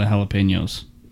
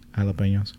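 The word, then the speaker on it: jalapeño: Jalapeno. That's how you say it. How else would you? – Not jalapeno. – Right. Yeah. Jalapeno. Because it's got the chk over the end.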